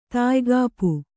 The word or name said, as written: ತಾಯ್ಗಾಪು